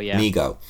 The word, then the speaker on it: Mego: you'd see that the toy company Mego, or you pronounce it Mego, right? – Mego.